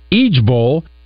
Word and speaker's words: IJBOL: Pronounced Ejbol, it means I just burst out laughing.